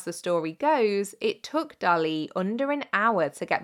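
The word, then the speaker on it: Dalí: It was in fact created by none other than Salvador Dali. Or Dali, if we're pronouncing it properly.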